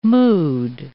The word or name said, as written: mood